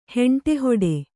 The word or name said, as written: ಹೆಂಟೆಹೊಡೆ